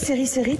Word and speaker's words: Série Series: C'est comme ça qu'il faut prononcer, Eva prononcer à la française, série, série.